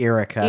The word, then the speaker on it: Yrica Quell: And so her name is Yrica Quell or Erica. I'm not I sure. think I think uh, internally Del Rey is pronouncing it Erica. – Erica. So Erica Quell um, she is an Imperial defector.